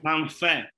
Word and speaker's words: Manfe: But Luca Man, how do you say the last name properly? – Manfe. So M-A-N-F-E.